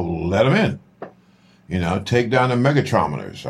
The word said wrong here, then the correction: magnetometers: I think that's, I'm saying that right. – Magnetometers. – Magnetometers. Yes. Thank you, big bro.